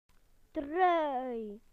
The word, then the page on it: jumper: British English